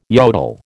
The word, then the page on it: yodel: American English